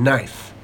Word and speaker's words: knife: In words starting with KN, the K is silent. Examples, no, knees, and knife.